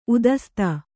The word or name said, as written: ಉದಸ್ತ